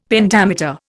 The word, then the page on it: Pentameter: Indian